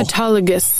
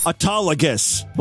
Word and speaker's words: autologous: How do you pronounce it? Hold on a second. I'm going to go right to Google. – Autologous. – Autologous. Boy, I butchered that.